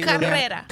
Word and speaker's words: Carrera: Am I saying that correctly? – Carrera. Roll your eyes.